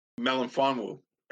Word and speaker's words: Melifonwu: Melifonwu.